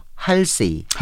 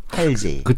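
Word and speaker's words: 할시: 국내 팬들에게 이름을 알린 팝스타죠 할세이. – 할세이. 예, 근데 음. – 어, 미국 사람들 DJ 그 미군 방송 들어보니까 할제이라고 발음하던데요. 할제이.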